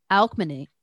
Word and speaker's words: Alcmene: According to the Iliad, on the day Zeus was expected to, Zeus was expecting alchemy. Why can't I say her name? Alcmene.